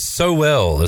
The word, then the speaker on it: Sowell: Russ says Sowell is how it's pronounced. – Sowell. – Chase Sowell. That sounds right.